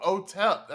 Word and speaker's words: Otep: Oh, Otep. That's right. That's how you pronounce that.